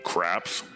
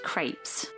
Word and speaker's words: crepes: Hey, I'm going to want to order four of these all-meat craps. – They're pronounced crepes.